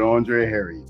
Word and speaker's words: Andre Herriott: Andre Herriott. I pronounced it incorrectly, did I? – It's all good. Andre Harry.